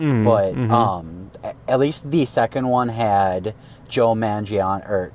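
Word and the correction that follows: Manganiello: No, Mangianiello. Mangianiello.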